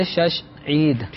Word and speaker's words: شش عید: تلفظ بگڑا ہوا تھا تو بگڑا ہوا تلفظ آگے نسل در نسل چلتا رہتا ہے. تو اصل لفظ ہے شش عید.